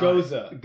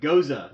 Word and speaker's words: Gose: Uh, finally got clarification on how to pronounce their name, and although I was incorrect, you guys were also way off as well. So it's the Margarita Oh, no, I kind of forgot. Gose.